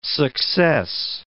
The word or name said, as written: success